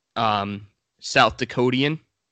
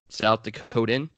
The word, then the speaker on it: South Dakotan: Yeah, we got Bryce Jobe, South Dakotian. I would assume that's how you would pronounce it, South Dakotian? – Yes. South Dakotan? – No. South Dakotan. – Dakotian would be incorrect.